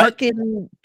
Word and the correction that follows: Harkonnen: Harkonnen. – Harkonnen.